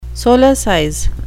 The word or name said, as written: solecize